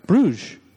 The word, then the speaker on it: Bruges: Yeah, how do you say that word? – Bruges. – Bruges. Bruges. Okay. I keep thinking burgers.